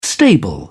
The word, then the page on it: stable: British English